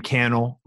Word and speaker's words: Cannell: Sean Cannell everywhere on social, S-E-A-N, Sean, and last name Cannell, rhymes with YouTube channel, spelled C-A-N-N-E-L-L.